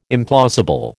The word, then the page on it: implausible: American English